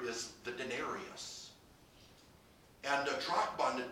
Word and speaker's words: drachma: The word in the original language is pronounced drachma.